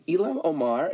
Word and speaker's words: Ilhan Omar: And by the way, Ilhan Omar, is that how you say her name? – Yeah.